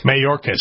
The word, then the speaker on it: Mayorkas: You know, Kevin McCarthy himself, last time he was at the border, said Mayorkas. I always say his name wrong. Mayorkas? – Mayorkas. Alejandro.